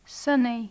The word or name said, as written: sunny